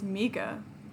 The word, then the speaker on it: Micca: It's actually the Italian way to say it is Micca.